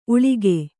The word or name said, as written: ಊಳಿಗಿ